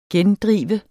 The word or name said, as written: gendrive